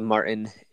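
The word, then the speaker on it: Maurten: It's called Martin. And I don't know if I'm saying that right, but it's M-A-U-R-T-E-N.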